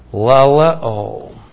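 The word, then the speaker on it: laleō: Laleo. L-A-L-E long O. Laleo.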